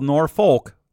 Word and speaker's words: Norfolk: Norfolk, Nebraska. It's, it's spelled Norfolk, like that place in Virginia, but it's pronounced Norfolk.